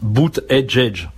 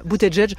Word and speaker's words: Buttigieg: Allez, entraînez-vous avec moi une dernière fois. Pete Buttigieg. – Voilà, dit comme ça, c'est facile. Buttigieg. Le truc, c'est que ça s'écrit pas du tout comme ça.